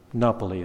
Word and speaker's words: Napoli: Napoli, that's how it is pronounced in Italian.